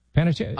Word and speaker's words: Panettiere: You know, I'm listening right now to the Hayden Panettiere. – Yeah. – Audiobook. Did I say it right? – Panettiere.